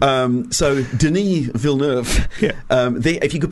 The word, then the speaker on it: Villeneuve: director Denis Villeneuve. If you could, is that how you pronounce it? It's how I'm pronouncing it's it. It's um, definitely. Oh, okay. Oh, I'm going with as in Jacques Villeneuve.